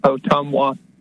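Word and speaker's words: Ottumwa: It's an Indian name. It's pronounced Otumwa, and it stands for rippling waters is what it stands for.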